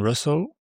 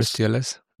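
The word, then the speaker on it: rustls: And so you started working on Russell. That's how you pronounce this, right? Because I always said RustTLS.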